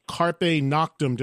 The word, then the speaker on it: Carpe Noctem: Of course, also the new Armored Saint live record, which is called Carpe Noctem. Did I say that right? – You did, I think, although I'm not a, you know, expert in Latin, but you sounded pretty good.